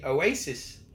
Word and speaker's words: oases: Would you say Oasises? – Unless I've mispronounced it, but it's spelled O-A-S-E-S. – No. I'm sure. It's like with fish. – Oh, like Oasis. Fish. – Oasis. Okay, perhaps that's how it's pronounced.